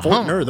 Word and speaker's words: Fortner: Fortner, the way it looks. Except like the T is kind of, is pretty silent. You don't really pronounce the T in Fortner. It's like very light T, very light T.